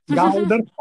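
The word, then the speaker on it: galdr: And Galder, got to pronounce it right, Galder, or not, as the case may be, as I spent much of my time not pronouncing it right.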